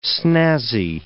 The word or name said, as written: snazzy